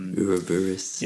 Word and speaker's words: Ouroboros: I think it was originally called, I'm probably going to say this wrong, is it Ouroboros? – Ouroboros. – Yeah. Ouroboros.